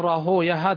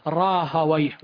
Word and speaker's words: راهويه: وأما إسحاق فهو ابن إبراهيم ابن مخلد أبو أيوب الحنظلي النيسابوري الإمام المعروف بابن راهوية، روى كما قلت بالأمس يقال راهوية، هذا نطق المحدثين، واللغويين يقولون راهويه، وهذا هو المشهور نعم.